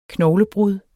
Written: knoglebrud

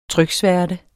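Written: trykværte

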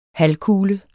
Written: halvkugle

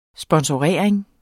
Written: sponsorering

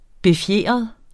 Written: BEFJEDRET

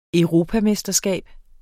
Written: EUROPAMESTERSKAB

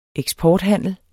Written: eksporthandel

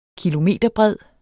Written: kilometerbred

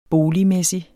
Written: boligmæssig

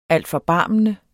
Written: altforbarmende

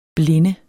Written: blinde

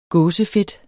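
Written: GÅSEFEDT

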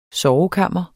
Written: sovekammer

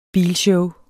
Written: bilshow

